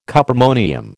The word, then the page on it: cuprammonium: American English